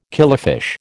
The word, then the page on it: killifish: American English